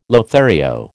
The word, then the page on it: Lothario: American English